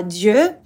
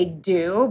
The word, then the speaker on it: Dieux: It's the French word for gods, Dieux, but we say do because it's easier in English and there are much better puns.